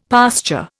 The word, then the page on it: pasture: Indian